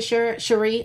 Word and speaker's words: Shuri: His speaking to Shuri, I keep wanting to call her Sherry, the way it's spelled.